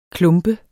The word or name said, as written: klumpet